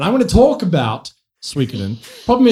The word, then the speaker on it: Suikoden: But for the love of God, the game is called Suikoden. That's how it's pronounced.